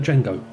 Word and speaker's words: Django: So we'd pronounce that Django. – Yep.